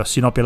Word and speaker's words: sinopia: Penso che la, parola, la pronuncia corretta sia sinoppia. Esatto, sì, immagino che sia proprio sinopia la parola giusta.